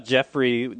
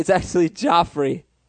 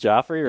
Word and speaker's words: Joffrey: How do you feel about Jeffrey, G-E-O, like that? – It's actually Joffrey. Joffrey, Benson, and Gerard. – Is it pronounced Joffrey, really? – Yeah.